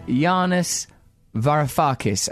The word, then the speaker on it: Yanis Varoufakis: My guest today is Yanis Varoufakis.